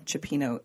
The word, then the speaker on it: cioppino: and I've been pronouncing cioppino incorrectly this whole time. – Oh, well, it's the Italian thing. – I called it cioppino. – Cioppino. – Yeah. – You pronounced every letter. – Cioppino. – Cioppino. – Yeah. – So I liked having that. – When you have the I and the O together with the C, I did not know this, but now I do.